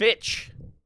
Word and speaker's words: Fitch: Abercrombie and Fitch. – Abercrombie and Finch. – It's a T, not an N, dude. – Oh, there's no N? – Fitch.